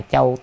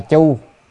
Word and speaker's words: châu: Chữ tiếp theo là châu tráp điều giang hay là châu táp điều giang. Chữ châu này nó cũng đọc là chu. Ở chỗ này đó đọc châu hay chu cũng được.